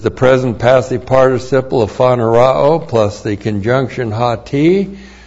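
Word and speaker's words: Phanerao (φανερόω): Phanerao. P-H-A-N-E-R-O, long O. Phanerao.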